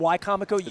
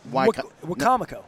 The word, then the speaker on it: Wicomico: I pronounce it wrong. I call it Wicomico. – It's Wicomico.